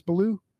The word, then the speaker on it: Ballew: I assume that's how you pronounce it, correct? That is Ballew? – You did it right off the bat.